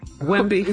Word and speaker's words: Wemby: Just Wemby. – Is what? – Victor. Victor Wembley. – Just call him Wemby, not Wembley.